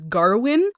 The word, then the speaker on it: Gawain: For the record, the movie pronounces the main character's name as Garwin. I've always said Gawain, and I'm going to probably say Gawain for this episode, just because that's what I know of.